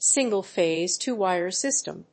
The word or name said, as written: single-phase two-wire system